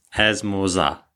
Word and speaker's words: esmorzar: But yeah, we don't pronounce those R's at the end of um, a lot of words. So yeah, as Morza. As Morza. Breakfast. – Esmorzar.